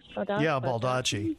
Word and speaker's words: Baldacci: I'm reading Split Second by David, I don't know how to say his last name, Baldacci? – Baldacci.